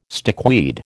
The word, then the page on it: stickweed: American English